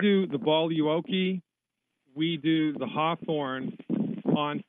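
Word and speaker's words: Boluoke: It's called Voluoki. You spell that B like baby, O like Oscar, L like Larry, U like uniform, O like Oscar, K like kilo, E. Voluoki.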